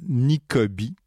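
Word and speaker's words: Nicoby: L'auteur s'appelle Nicobi, exactement comme ça se prononce, avec un Y pour le I final.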